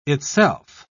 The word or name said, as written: itself